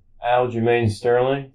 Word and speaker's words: Aljamain Sterling: But the next big event going at the Garden is technically the UFC fight in August, which is Sean O'Malley versus Aljamain Sterling.